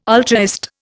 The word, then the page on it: ultraist: Indian